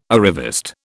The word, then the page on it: arriviste: British English